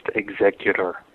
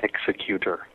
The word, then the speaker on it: Executor: It is pronounced executor. It is not executor. There is no such word as executor. The word you would use for that is executioner.